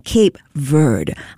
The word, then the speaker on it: Cape Verde: For English speakers, the correct pronunciation actually rhymes with Cape Verde. Yes. Similar to the word bird. So not Verde, but Cape Verde.